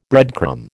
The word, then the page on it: breadcrumb: American English